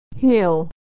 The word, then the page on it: hell: American English